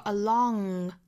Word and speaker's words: along: 所以说 along 和 alone 是有不同的发音的。